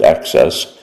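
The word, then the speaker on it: AHCCCS: Arizona's Medicaid agency, the Arizona Health Care Cost Containment System, or AHCCS, pronounced access, enrolls about 1.8 million state residents.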